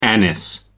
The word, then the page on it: anise: American English